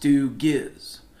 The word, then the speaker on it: Stooges: Another name for human in the language of the Sasquatch is Stooges, pronounced Stoogiz, roughly translated messy folk.